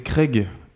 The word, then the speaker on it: Craig: Greg Mazin, ça, ça sonne tellement francophone. C'est Craig. Craig, Mazin, I guess.